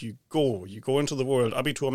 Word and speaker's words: Abitur: It's called the Abitur.